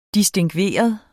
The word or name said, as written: distingveret